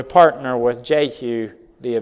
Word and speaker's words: Jehu: Jehu. By the way, the literal translation, the literal pronunciation of his name would be Yehu.